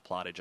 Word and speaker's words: Plottage: And for those of you that are new to the areas, it looks like Platt Deutsch, but it's actually pronounced Plottage.